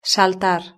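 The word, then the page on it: saltar: Spanish (Spain)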